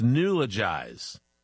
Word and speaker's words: neologize: It's spelled N-E-O-L-O-G-I-Z-E. N-E-O-L-O-G-I-Z-E.